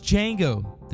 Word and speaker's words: Django: That, of course, was Django, the D is silent, directed by Mr. Quentin Tarantino.